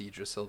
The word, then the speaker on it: Yggdrasil: There are two Gs. – Yeah, it's pronounced Yggdrasil, though.